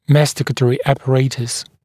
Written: masticatory apparatus

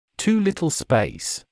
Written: too little space

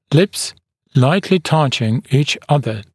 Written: lips lightly touching each other

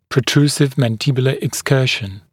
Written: protrusive mandibular excursion

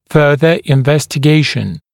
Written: further investigation

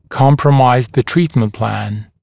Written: compromise the treatment plan

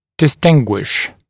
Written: distinguish

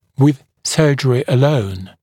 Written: with surgery alone

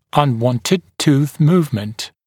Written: unwanted tooth movement

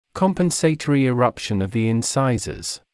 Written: compensatory eruption of the incisors

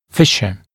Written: fissure

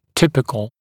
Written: typical

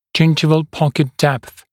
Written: gingival pocket depth

